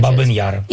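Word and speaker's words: Babyn Yar: How do you pronounce the name of the monument in your language? – Baban Yar.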